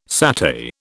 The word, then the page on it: satay: British English